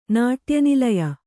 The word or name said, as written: ನಾಟ್ಯನಿಲಯ